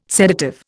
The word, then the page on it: sedative: Indian